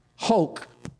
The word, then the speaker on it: hok: Set time is the Hebrew word hok, which ain't too far off from choke. In fact, in the Hebrew, it's spelled C-H-O-Q, and then the transliteral to our language is K-H-O-K-E.